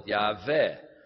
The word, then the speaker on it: Yahweh: I have to think when I say that because in German we call it Yahweh because it's a W and it's pronounced like a V.